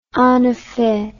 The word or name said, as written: en effet